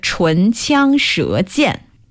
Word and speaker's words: 唇枪舌剑: It is pronounced 唇枪舌剑.